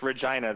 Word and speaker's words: Regina: No, it's Regina, the city that rhymes with fun.